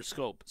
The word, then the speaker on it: Schoop: the Tigers signed a deal to with Jonathan Schoop and a one-year deal – or Scope, sorry, Jonathan Scope, as somebody pointed out. Yeah, somebody actually on Twitter, Lee Panaris, or Lee on Twitter, mentioned how to say his name.